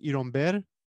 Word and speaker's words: Ironbeer: I am I am a teetotaler, but I am drinking something in honor of the spirit, a, a Cuban soft drink called Ironbeer, uh, which in Cuba would be pronounced iromber uh,